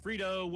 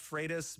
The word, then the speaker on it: Freitas: Technically, it's pronounced Freitas, but my drill sergeant Freitas taught me to respond to anything close. – all right. Freitas is the right way to say it.